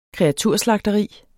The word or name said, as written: kreaturslagteri